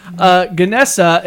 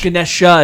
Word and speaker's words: Ganesh: It's not Ganesha. It's Ganesh. – Ganesha is another word for it, but in another pronunciation, it's Ganesha. Oh. And there's also Ganesh. That's another way of saying it.